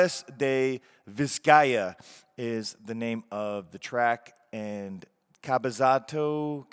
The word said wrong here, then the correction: Cabezabota: I don't know why I keep saying that incorrectly. – Cabezabota. – There you go.